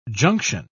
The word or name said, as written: junction